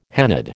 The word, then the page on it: hennaed: American English